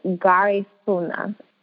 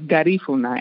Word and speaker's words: Garifuna: What is Garifuna? I know I said that wrong. – You were close. Very, very close. So it's Garifuna.